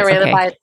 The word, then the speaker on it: Ramabai: I don't know if I'm saying her name correctly, but Pandita Ramabai, is that how Pandita Ramabai Saraswati.